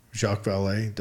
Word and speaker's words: Jacques Vallée: Jacques Vallée, did I say that right? – Yes.